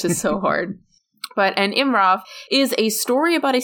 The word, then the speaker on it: immram: So, about this genre, specifically for Irish stories, this tale is called an immram, which is spelled I-M-M-R-A-M. So it looks like immram, but my professor, my Irish professor, always said immram.